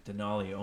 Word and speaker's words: Denali: Denali almost rhymes with tamales.